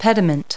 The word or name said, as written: pediment